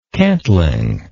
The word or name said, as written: cantling